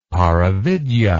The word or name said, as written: paravidya